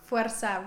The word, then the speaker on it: Forza: As Maria Fay said, this is Forza. Did I say that right? – Forza, roll your R's. – Arr, yeah. – Like a machine gun.